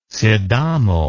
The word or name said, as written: sidamo